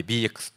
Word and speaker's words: BEEX: 我々の会社は BEEX と書いて BX と読みますが、 2016 年に創業した会社になります。